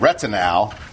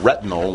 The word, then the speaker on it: retinal: And this operates on the basis of a pigment called retinal. And I pronounce it that way rather than retinal, which means of the retina, because retinal is an aldehyde, ends in AL.